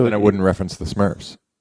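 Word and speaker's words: SMIRFS: Smurfs, pronounced Smurfs, but it's S-M-I-R-F-S, standing for SModCo Minor Internet Radio Fans.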